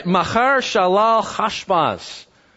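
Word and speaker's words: Maher-shalal-hash-baz: Machar Shalal Hashbaz. And Hebrew has that guttural H, you know, like you're getting ready to Sorry, ladies, like you're getting ready to spit.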